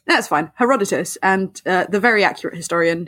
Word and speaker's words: Herodotus: Herodotus, that renowned, very accurate historian. – Herodotus.